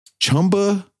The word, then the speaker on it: Chumbawamba: It's not. It's like La Bamba. – Chumba. – It's a soft A.